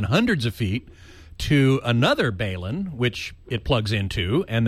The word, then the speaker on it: balun: And I've been experimenting with different balloons to transmit the HDMI signal through the Ethernet cables. – Sure. Just before you get any further, it's pronounced Balun. – Balun. – Balun. It's B-A-L-U-N for balanced, unbalanced.